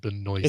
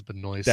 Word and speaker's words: Benoist: It's Benoist. No, it's pronounced Benoist. – It's Benoist, right? – Yeah, it is Benoist.